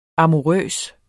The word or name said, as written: amourøs